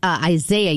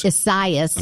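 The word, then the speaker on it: Isaias: I think I was calling it Isaiah yesterday. No, it's Esaias.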